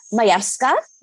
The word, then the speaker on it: Majewska: Majewska, is that correct? – Perfect.